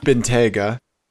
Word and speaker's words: Bentayga: Bentayga? – Bentayga with a vanity plate.